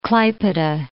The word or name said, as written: Klaipeda